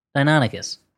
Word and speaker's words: Deinonychus: Deinonychus.